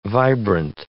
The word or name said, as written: vibrant